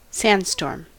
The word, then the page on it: sandstorm: American English